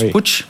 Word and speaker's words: Puig: Un autre élément intéressant aussi, qui va être intéressant pour Interparfums, c'est la possible introduction à venir du groupe espagnol qui s'écrit Puig et qui se prononce Puig, qui va aussi permettre d'avoir un petit peu plus d'acteurs cotés dans ce secteur.